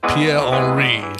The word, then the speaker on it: Pierre Henry: Pierre Henry. – It's this. – Henri, probably pronounced Pierre Henri.